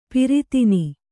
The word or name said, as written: ಪಿರಿತಿನಿ